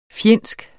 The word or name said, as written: fjendsk